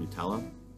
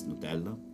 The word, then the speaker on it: Nutella: So if you know what Nutella is, I know you might say it Nutella, but in Italian it's Nutella.